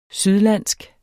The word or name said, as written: sydlandsk